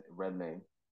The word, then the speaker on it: Redmayne: Redmayne.